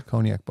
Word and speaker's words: Cognac: How do you get, it's C-O-G-N-A-C. How the fuck? – But it's not. – Cognac. That's how you pronounce it. K-O-N-Y-A-K. – But it's not a C.